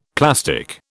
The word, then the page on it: plastic: British English